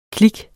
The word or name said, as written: klik